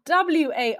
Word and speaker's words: Warwick: And I'll give you a little hint that you need to remember that it's spelled Warwick, like the county in the UK. So I'll head on over to the episode. Hi, hi, hi, Jo Warwick here, and welcome to today's podcast.